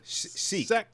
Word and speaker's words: Sikh: She accused for silk. Is that how you say it? Silk? – Sikh. – Sikh or Sheik? – I apologize. – Muslim? – Sikh. – They're not men. Muslim. They're Sikh.